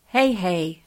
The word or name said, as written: Heihei